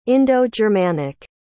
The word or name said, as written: indo-germanic